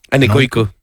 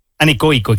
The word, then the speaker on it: anecoico: Anecoico. – Anecoico, exactamente.